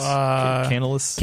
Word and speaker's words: Canalys: One of them's from Tech Power Up, talking about the forecast from Canalysis. How would you say this? – Canalys. – Okay. – Canalys. – Yeah.